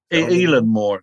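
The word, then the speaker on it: Eilean Mor: Is it Eileen Moore, the island? – Eileen Moore, it's pronounced.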